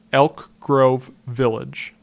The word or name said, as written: Elk Grove Village